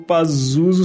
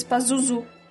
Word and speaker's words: Pazuzu: Uma pequena correção ao capítulo 85, quando vocês citaram o demônio Pazuzu, a pronúncia do nome está errada. Na verdade, eles pronunciam algo como... Agora ele me quebrou aqui, porque... Ele disse que a pronúncia é algo como Pazuzu, só que ele botou acento nos dois U's.